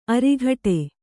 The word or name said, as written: ಅರಘಟ್ಟ